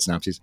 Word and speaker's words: synopses: You know, sometimes I'm reading through episode synopses. Is that how you say it? Synopses. – Synopses. I think that's a plural. Synopses.